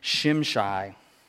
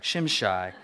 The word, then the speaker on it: Shimshai: As soon as the copy of the letter of King Artaxerxes was read to Rehum and Shishim, oh, uh, I didn't pronounce this in advance here, Shimshai, I'm sure I'm getting that right, Shimshai, the secretary and their associates, they went immediately to the Jews in Jerusalem and compelled them by force to stop.